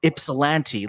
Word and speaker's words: Ypsilanti: So I heard about Ypsilanti.